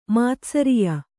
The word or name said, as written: ಮಾತ್ಸರ್ಯ